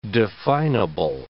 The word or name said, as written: definable